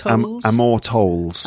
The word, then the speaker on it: Amor Towles: It's A Gentleman in Moscow by, I'm not quite sure how to pronounce his name. Amor Towles? – That's right. Towles. Amor Towles.